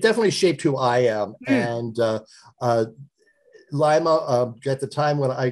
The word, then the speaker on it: Lima: It is Lima, L-I-M-A, and it stands for Lost in Middle America.